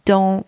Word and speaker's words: dans: First word, dans, spelled D-A-N-S, means in. The letters A and N together are pronounced en in that word, and the final S is silent. With the second word, dent, spelled D-E-N-T, the letters E and N together are also pronounced en, and the final T is silent.